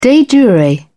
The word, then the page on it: de jure: British English